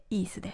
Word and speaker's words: EASE: 目黒のイースという場所で行ってます。 EASE と書いてイースです。